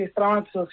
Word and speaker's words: Estramazo: Sí, Estramazo, sí. – Vale. – Estramazo... En Argentina solemos decir Estramazo igual, sin pronunciarlo tanto en italiano.